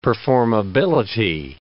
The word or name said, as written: performability